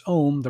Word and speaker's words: Om: Om, pronounced Om, the primal cry, it means it is, will be, or to become.